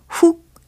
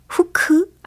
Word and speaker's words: hook: 이 중에서 이 후크, 후크라고 많이들 말씀을 해보셨을 텐데, 영어 단어 H-O-O-K 이거잖아요. 그죠? H-O-O-K 발음을 영어식으로 정확히 한번 해보세요. 훅 아니고, 후크 아니고, 바로 훅 이겁니다. 훅 들어오는 거 아니고요. 후크 아니고요. 훅 이렇게 하신다고요. 끝부분에 크, 크, 크 이렇게 끝난다는 거.